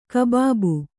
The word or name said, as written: ಕುಬುಬು